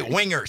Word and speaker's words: Wenger: I knew either the N or the G. Is one of them silent, Alicia? I think I picked the wrong one. – You just gotta chunk it out like Wang. – Okay, Wenger. So there's the G is not pronounced.